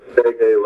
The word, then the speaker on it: Nadege: You say Nadege like to pronounce the N or E and D K and reggae like reggae.